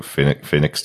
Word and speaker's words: Phoenix: So Phoenix doesn't rhyme with penis.